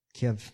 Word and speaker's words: Kyiv: Kyiv.